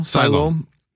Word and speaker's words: Siloam: Of course, we've hosted radiothons here on 680 CJOB over the years to help Siloam Mission. Am I saying that right correctly now? – Siloam Mission.